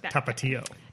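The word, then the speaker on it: Tapatío: And I thought, you know, if I were listening to this podcast and I were that guy, I'd be like, it's pronounced Tapatio. – Just like slamming tapatio. – Your head. – Tapatio. That's why the accent is over the last I.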